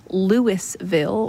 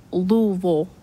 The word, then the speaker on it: Louisville: They, anything that has a vill at the end, they say vol, like Louisville, Kentucky. It looks like Louisville or Louisville, but Louisville.